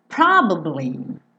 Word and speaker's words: probably: Probably é uma palavra que tem três sílabas, só que essa sílaba do meio, ela é bem discreta, ela é bem escondidinha, tá bom?